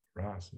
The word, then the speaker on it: Rasa: Rasa is a long A.